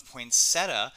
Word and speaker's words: poinsettia: trashy um pronunciation of poinsettia. Uh, mm-hmm. Uh, that being said, I do know there is an I in that word, and it's yeah. unlikely that it's silent.